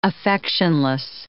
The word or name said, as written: affectionless